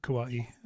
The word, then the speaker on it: Kauai: Kauai or Kauai. I like Kauai. That's the more legit pronunciation.